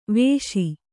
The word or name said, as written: ವೇಷಿ